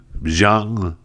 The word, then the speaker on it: Jeong: And so during the taping, two of the judges walk out, Robin Thicke and Ken Jeong. Is that how you pronounce his last name? J-E-O-N-G.